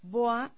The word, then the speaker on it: Bois: Y nos encuentran, como ya lo habíamos dicho, en Instagram como BOA Complementa Tu Estilo y en Facebook como BOA Centro de Equilibrio. – BOIS, B-O-I-S. – Sí, se escribe así. – BOIS. A los que no hablamos francés.